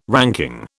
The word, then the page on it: ranking: British English